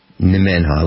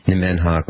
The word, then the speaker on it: Nemenhah: Okay, so why don't we take a couple minutes and discuss exactly Nemenha.